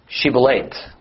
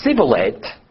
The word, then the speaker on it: Shibboleth: Every male who was trying to ford the Jordan was required to say the word Shibboleth. If they couldn't properly pronounce it and instead said Sibboleth, then it identified them as an Ephraimite and they were killed on the spot.